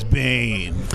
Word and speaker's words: Biehn: Well, you know the real way to pronounce his name is Biehn.